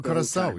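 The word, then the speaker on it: Curaçao: You know, I didn't know how to say the I was calling it Caracal. – Oh, Curacao, yeah.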